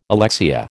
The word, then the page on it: alexia: American English